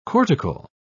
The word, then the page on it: cortical: British English